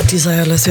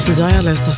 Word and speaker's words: Desireless: Nein, das ist eigentlich ein englisches Wort. – Die sei ja Les. – Aber viele sprechen es halt französisch aus.